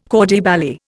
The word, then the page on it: corps de ballet: Indian